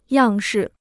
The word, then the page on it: 样式: yàng shì